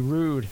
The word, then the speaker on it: Roode: You're going to have Sting facing off against the TNA World Heavyweight Champion, Bobby Roode.